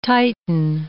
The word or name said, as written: titan